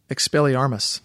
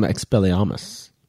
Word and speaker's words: Expelliarmus: Expelliarmus. – Interesting. You say the R. I see I'm Expelliarmus. – Well, they're British.